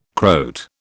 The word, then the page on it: Croat: British English